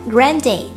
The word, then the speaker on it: Grande: 另外 Grande 词末的 e 不是不发音也不是发轻音而是要发 a 的音 Grande 要注意重音的位置哦 Jamie